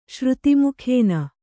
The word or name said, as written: ಶ್ರುತಿಮುಖೇನ